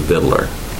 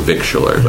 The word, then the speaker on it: victualler: Right now we require a license for the word I pronounce it vittler. – Okay. – But it's commonly pronounced here and elsewhere too, victualler. But I think the dictionary pronunciation is vittler, and that's the way I pronounce it.